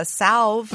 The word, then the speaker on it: salve: And they even have a salve. – Mm-hmm. – I pronounce the L. I don't know about you guys. You losers. Leave out the L.